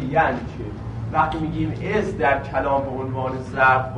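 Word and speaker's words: إذ: پس از درست مبنیه و مبنیه بر سکون هم هست، میگیم از، زالش رو ساکن تلفظ میکنیم، مبنیه، مبنیه بر سکون هم هست، ولی وقتی تو کلام مشاهده شد در این معنای ظرف بودنش، باید محلن منصوبش بدونیم.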